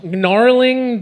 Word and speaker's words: gnarling: It's gnarling their gonads. – Their gonads. It's gnarling their gonads. – I bravely went for a silent G.